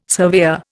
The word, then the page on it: surveyor: Indian